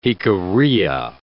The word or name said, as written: Jicarilla